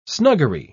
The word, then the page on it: snuggery: British English